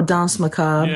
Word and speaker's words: Danse Macabre: He wrote a book called, I don't even know how to pronounce it, Danse Macabre, I guess? – Oh, Danse Macabre.